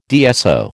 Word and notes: DSO: American English

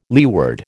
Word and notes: leeward: American English

